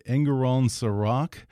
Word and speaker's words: Engerraund Serac: And in the show, you play a mysterious tech mogul, and I hope I pronounced this right, named Engerraund Serac.